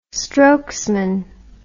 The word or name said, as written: strokesman